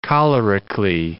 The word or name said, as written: cholerically